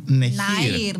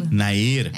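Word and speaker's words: Nehir: Τι, Νεχίρ. – Ναΐρ. Έτσι, προφέρετε.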